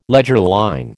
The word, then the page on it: leger line: American English